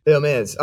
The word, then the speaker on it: Hermès: You say Hermès, or how it's a French company, so you've got to say it, Hermès, but I'm not going to say that. – That's well, without the H. – Hermès.